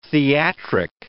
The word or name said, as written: theatric